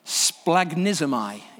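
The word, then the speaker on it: Splagnismai (σπλαγχνίζομαι): The Greek word is actually very difficult to pronounce. There's a lot of consonants right there in the middle. Splagnismai is the middle voice that's used. Splag.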